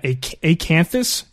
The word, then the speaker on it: Acanthus: He has a website at Acanthus. Am I pronouncing that correctly? They say it. That sounds how I pronounce it.